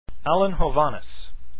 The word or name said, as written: hovhaness